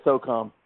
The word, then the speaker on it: SOCOM: How do you say it? – I just say SOCOM. – SOCOM. Oh, U.S. SOCOM.